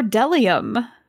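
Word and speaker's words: bdellium: B is for delium. We doubt anyone knows what delium is, but it's the only word dumb enough to begin with a silent B.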